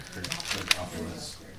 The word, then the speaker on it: Areopagus: Areopagus.